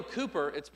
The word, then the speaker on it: Cowper: William Cooper, it's spelled C-O-W-P-E-R, but pronounced Cooper, who was a good friend of John Newton, who wrote Amazing Grace.